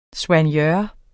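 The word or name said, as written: soignør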